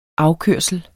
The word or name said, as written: afkørsel